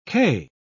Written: kay

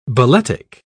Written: balletic

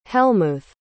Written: helmuth

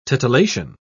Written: titillation

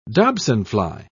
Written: dobsonfly

